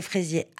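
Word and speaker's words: Frézier: Ce sont des fraisiers à gros fruits blancs qui ont été ramenés du Chili par M.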